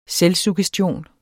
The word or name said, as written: selvsuggestion